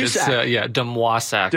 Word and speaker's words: Demosak: Demosak. – Demosak. – Yeah, like Patrick Waugh, Demosak.